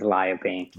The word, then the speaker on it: Li-a-Ping: First name right. Last name is LePink.